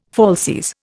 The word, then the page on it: falsies: Indian